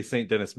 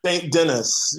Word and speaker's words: St. Denis: so I'm saying it wrong if I say St. Denis Medical, right? It's not. – St. Denis.